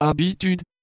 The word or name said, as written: habitude